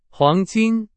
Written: 黄金

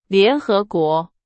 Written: 联合国

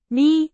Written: 迷

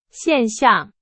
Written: 现象

